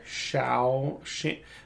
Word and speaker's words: Shaoxing: A lot of recipes for Chinese food will use something called Shaoxing wine, which is not spelled the way I just said it. But that's how you pronounce it. – Shaoxing. Oh, so you have to know that the X sound. So in Chinese, X makes a she sound when you're looking at it in English writing.